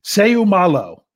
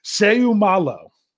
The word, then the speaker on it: Seumalo: But Isaac Seumalo is the way I'm going to say it. Seumalo.